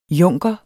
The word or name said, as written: junker